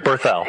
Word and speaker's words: Berthel: Berthel.